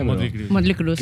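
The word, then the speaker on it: Mötley Crüe: Motley Crue, ¿no? No sé cómo se pronuncia, Motley Crue,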